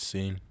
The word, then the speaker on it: Cine: It's Lewis Cine.